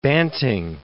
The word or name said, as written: Banting